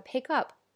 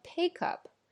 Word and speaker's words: pick up: phrasal verb，比如说我们之前提到的 pick up，它的发音呢，讲要注意什么呢？就是你的重音要在放在后边的这个介词上，我们不能说 pick up。